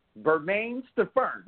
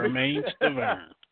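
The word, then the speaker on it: Bermane Stiverne: Bermaine Stavern. Did I correct it? Did I say it right this time? Was the sucker Absolutely.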